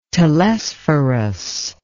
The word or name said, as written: telesphorus